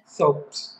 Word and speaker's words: thobe: And it was like a sea of black and white, you know, black with the burqas and white with the thobe. Is that how you pronounce it? – Thobes, yeah.